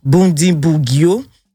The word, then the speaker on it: Bundibugyo: L'épidémie est provoquée par le virus Ebola du type Bundibugyo.